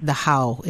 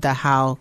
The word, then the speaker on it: Dachau: And so when we went to Germany in this trip, we went to Dachau, or I think I'm saying it, Dachau is the proper pronunciation, Dachau concentration camp. I kept calling it Dachau,